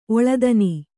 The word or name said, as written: ಒಳ್ತನ